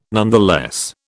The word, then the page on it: nonetheless: British English